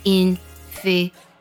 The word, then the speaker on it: inferiority: 自卑这个单词呢比较难读，它读作 inferiority。 Inferiority, 读的时候呢 PY 这个音节都可以做个美式浊化